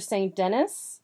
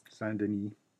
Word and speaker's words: Saint-Denis: and we have the surveillance section again saying, after visiting the theater, they strolled down the Rue Saint-Denis. – Saint-Denis.